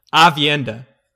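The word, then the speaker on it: Aviendha: I really liked, again, I don't know how to pronounce her name right, Avienda? – Avienda. – Yeah. Avienda. – Okay, I changed my answer immediately. Name. Avienda is clearly the best pick. – Avienda is such a... – Nope, so Avienda is the best.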